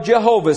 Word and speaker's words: Jehovah: So now you've got this safe to speak and write name of Jehovah.